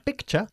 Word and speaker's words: picture: Pronunciación, picture y pictures. All words that end in T-U-R-E are pronounced ch, okay? Not ture, ni tur. It's ch, like picture.